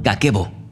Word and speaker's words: Kakeibo: Se llama Kakebo, aunque se escribe Kakeibo.